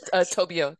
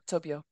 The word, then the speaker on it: Tobio: Yeah, Tobio. Tobio.